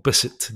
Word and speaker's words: Bissett: I have to say, most people go Bissett. They're not sure if there's a French variation, but it is just plain old Bissett, John Bissett.